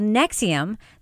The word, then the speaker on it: NXIVM: Nexium, that is spelled, by the way, N-X-I-V-M, all capital letters.